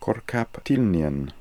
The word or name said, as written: Korkaptilnian